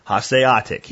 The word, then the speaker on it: Hanseatic: Hanseatic. He's got it phonetically spelled out for me because he knows I'll never pronounce that right.